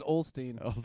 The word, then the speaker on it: Osteen: It's pronounced Osteen. And I don't know why you got to be... – It's Osteen, not old. There's no L.